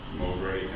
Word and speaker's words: Mowbray: What I would first like to do, and by the way, my last name is spelled for the record, Mowbray, M as in Mary, W-B-R-A-Y, and I'm here representing several neighborhood owners in opposition to this item.